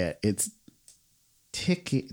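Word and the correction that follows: tikka masala: No, hold on. Tikka masala.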